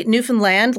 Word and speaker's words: Newfoundland: It's actually Newfoundland, like understand. – Newfoundland, like now I understand. – Exactly. – So can we hear a little of this Newfoundland, you know, the province we're talking about? – Newfoundland.